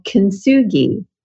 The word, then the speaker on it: Kintsugi: It is called Kintsugi.